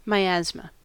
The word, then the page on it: miasma: American English